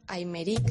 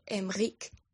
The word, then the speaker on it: Aymeric: Os llamaba para informaros que Aymeric, según vosotros, no se dice así. Se dice Emric. Sé que parece más Enrique, pero es así como se dice, correcto.